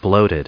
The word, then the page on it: bloated: bloat*ed; American English